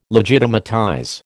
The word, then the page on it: legitimatize: American English